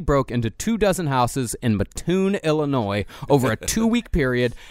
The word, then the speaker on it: Mattoon: But you have to say it correctly. It's the Mad Gasser of Mattoon. Have you seen the gasser on that Mattoon over there? – Yes, indeed.